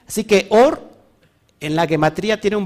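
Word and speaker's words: Or (אור): Luz en hebreo se escribe Aleph Bat Reish y suena como Or.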